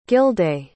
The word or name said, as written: gildea